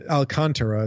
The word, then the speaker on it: Alcantara: Yeah, it's actually Alcantara is how he pronounces it.